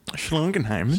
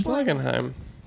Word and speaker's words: Schlagenheim: And I'm like, that's a Schlagenheim? – Yes. Thank you for pronouncing it. – Yeah. – Schlagenheim. – Schlagenheim.